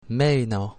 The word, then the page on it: meno: Italian